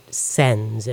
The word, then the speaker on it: SENS: But perhaps we could start with some of the basics here about your project and about SENS, if I'm saying that right, S-E-N-S.